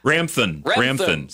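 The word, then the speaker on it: Ramthun: And Rathman. – Rathman. – Is it Rathman? Did I say that right? – It's like, Ramthan. Sorry.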